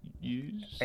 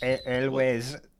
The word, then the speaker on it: Elwes: Kerry Hughes? – Elwes.